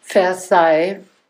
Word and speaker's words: Versailles: Versailles. Vers aber es wird doch, das Schloss L Versailles. spricht man nicht.